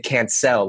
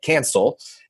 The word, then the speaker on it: Cancel: I think mainly because his name is actually pronounced David Cancel, like I can sell, but people Americanized his name and called it Cancel.